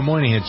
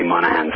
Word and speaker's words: Monaghan: Do you pronounce it Monaghan or Moynihan? Some people say Monaghan. – Yeah, it's Monaghan. It's a county in Ireland.